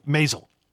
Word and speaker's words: Maisel: And the fact that the woman who actually plays Mrs. Is it pronounced Maisel or Maisel? – Maisel.